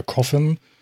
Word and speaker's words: Coffin: Coffin der Name schreibt sich tatsächlich so und spricht sich anscheinend auch genauso aus wie der Coffin und äh, Kyle Balder.